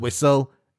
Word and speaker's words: whistle: The correct spelling is W-H-I-S-T-L-E. – You have to remember those silent letters. It can be difficult to remember that H and the T.